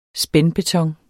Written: spændbeton

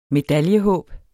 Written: medaljehåb

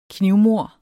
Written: knivmord

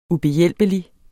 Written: ubehjælpelig

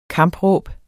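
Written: KAMPRÅBET

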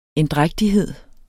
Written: endrægtighed

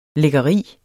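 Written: LÆKKERIER

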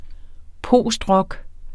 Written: postrock

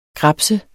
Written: grabse

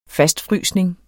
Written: fastfrysning

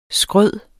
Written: skryde

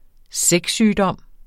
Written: sexsygdom

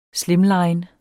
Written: slimline

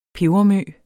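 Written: PEBERMØ

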